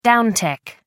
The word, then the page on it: downtick: American English